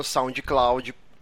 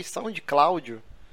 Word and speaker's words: SoundCloud: Toda segunda-feira você pode baixar o MP3 no seu feed de escolha ou lá no SoundCloud Sound Cláudio. Eu gosto, eu sempre me confundo. Porque eu ouço os programas depois e eu falo, caralho, eu falei Sound Cláudio. É uma, uma, pronúncia desgraçada, mas vamos, vai ficar isso aí.